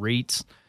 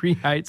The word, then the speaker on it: REIT: For those of you listening to the show, we're talking about REITs. So, and Middle America says REIT. Just REIT.